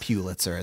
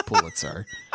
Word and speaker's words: Pulitzer: First, he talks about how he'd been pronouncing it wrong his entire life, and it's not Pulitzer, it's Pulitzer.